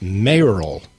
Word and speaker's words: mayoral: And so it's mayoral. – Mayoral. – Because you're running for mayor.